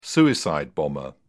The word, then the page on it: suicide bomber: British English